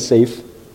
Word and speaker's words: Saif: My name is Saif, S-A-I-F. My last name is Mahmoud, M-A-H-M-O-U-D.